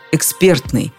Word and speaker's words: экспертный: Правильно, конечно же, эксперт, экспертный. Словари дают именно такое ударение, как единственно правильное. Вариант, который прозвучал в телестудии, сопровождается восклицательным знаком и пометой «не рекомендуется».